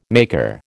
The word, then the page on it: maker: American English